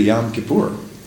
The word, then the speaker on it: Yom Kippur: We say Yom Kippur. He says it's really Yom Kippur.